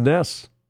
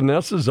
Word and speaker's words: Njos: NJOS is pronounced Ness.